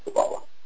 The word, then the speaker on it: Kabbalah: The English is Kabbalah. The difference is in Hebrew the stress is on the second syllable.